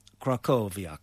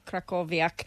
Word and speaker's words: Krakowiak: So this is, now I looked up the pronunciation on the internet, and it's Krakowiak, which is a Polish dance.